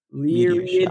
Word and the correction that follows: Lyrids: It's like lyrics, except instead of C, it's a D. – Shower. Too late. – Lyrids.